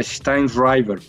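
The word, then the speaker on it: Sztajnszrajber: Tiene un apellido escrito muy extraño, con Z y J y demás, pero se pronuncia Steinreiber, pero se escribe muy raro.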